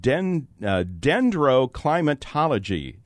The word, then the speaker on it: dendroclimatology: Tell us about the science of dendroclimatology.